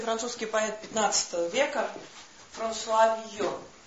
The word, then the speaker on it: Вийон: Просто чтобы вам было понятно, и мы больше к этому не возвращались, существует две традиции записи иностранных имен. Подчиняясь фонетике, вот как раз Вийон, и подчиняясь тому, как написано на бумаге.